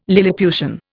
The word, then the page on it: lilliputian: Indian